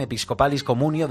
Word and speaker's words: Episcopalis Communio: Uno de ellos es la Constitución Apostólica Episcopalis, a ver si lo digo bien, Episcopalis Communio del Papa Francisco.